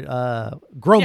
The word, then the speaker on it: Gromek: Because Paul Newman, after this pot of hot soup is thrown at poor Gromack... How do I say his name here? Gromack. – Yeah, Gromack. Yeah, you're right.